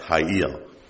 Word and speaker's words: chayil: and the transliterated word is spelled C-H-A-Y-I-L, and it's pronounced Hayil. How they get Hayil out of that, I don't know, but that's what they did.